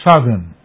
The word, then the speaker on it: southern: Our next word is spelt S-O-U-T-H-E-R-N. – Southern. Southern. And not southern, as we often hear in both the northern and southern parts of this country.